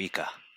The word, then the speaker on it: ICA: T'atreveixes uh, a pronunciar-ho, o què? – Es diu Ica.